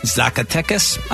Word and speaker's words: Zacatecas: Zacatecas, I believe is how you say it.